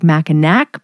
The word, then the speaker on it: Mackinac: It looks like Mackinac, but it's pronounced Mackinac.